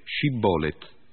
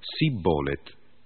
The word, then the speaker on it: Shibboleth: Ma i Galaaditi imponevano loro di dire Shibboleth, ed essi rispondevano Sibboleth, perché non sapevano pronunciare correttamente la parola.